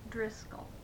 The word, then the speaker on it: Driskell: Harp, B-A-R-B-A-R-A, the initial K, H-A-R-P. – And what was your maiden name? – Driscoll. – And would you spell that, please? – D-R-I-S-K-E-L-L.